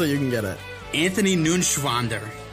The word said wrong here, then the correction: Neuenschwander: Ah, you didn't pronounce it right. An- Anthony. Anthony Neuenschwander. It's, it's N E U E N. It's Neuenschwander. Anthony Neuenschwander.